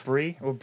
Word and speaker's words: Brie: Brie? Ah, it's spelled like the cheese.